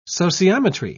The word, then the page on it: sociometry: British English